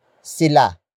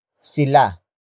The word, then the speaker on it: sila: After that, we have sila. Sila. Sila. Sila is spelled as S-I-L-A.